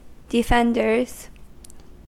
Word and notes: defenders: American English

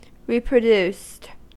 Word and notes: reproduced: American English

